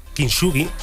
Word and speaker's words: kintsugi: que es el arte kintsugi, lo he dicho bien.